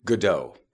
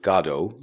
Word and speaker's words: Godot: So Mr. Beckett, I'm not sure I ever heard him say the name of the play, but I presume he pronounced it Godot, like, you know, the English usually say, well, you know, your Godot play. Or the Irish say, your man Godot and his fancy play. Like, they put the accent and the pronunciation that way. To Americans, sometimes that sounds a little too much nail on the head, like we're referring to God.